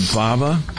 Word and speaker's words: Vava: My New Swag from, is it Vava? – Yeah. – Is that how we pronounce the name? – That's right.